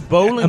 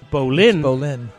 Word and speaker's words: Boleyn: The bowling Americans. – Ground. When they pronounced the bowling ground, when you pronounce it Boleyn, it sounds totally different.